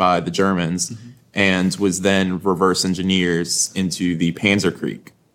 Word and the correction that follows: Panzerschreck: which I think I'm saying that right. – Panzerschreck.